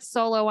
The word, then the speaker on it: Soleau: I'm the only Meredith Soleau online, but my last name is spelled S-O-L-E-A-U.